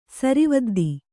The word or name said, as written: ಸರಿವದ್ದಿ